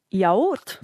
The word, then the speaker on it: yaourt: But Nikki says in French it is yaourt. – Yaourt, yes. – Yaourt. Oh, bonjour, Nikki. Lovely to have you along tonight. – It's A-O-U in French, so that they've got to pronounce all their vowels, you see.